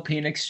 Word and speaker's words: Penix: Holy shit, Michael Penis Jr. can throw the ball. Oh, did I say it wrong? Did I say penis? – Yeah. – Michael Penix Jr. can throw the ball.